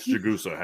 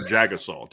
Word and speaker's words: Jagusa: The official pronunciation for Irish Breakdown and Charles Jagusa has changed. Without a statement. Yeah. It is no longer Jagasaw.